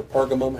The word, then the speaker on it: Pergamum: But we move on today to a church called Pergamon or Pergamum, however you want to pronounce that.